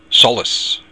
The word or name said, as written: solas